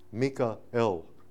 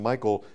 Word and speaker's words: Michael: The name itself, Mika-el, Michael, means one who is as God. So the English pronunciation, Michael, comes right out of the text of the older Hebrew scriptures, you see.